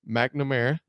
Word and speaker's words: McNamara: but they got former Michigan quarterback Cade McNamara.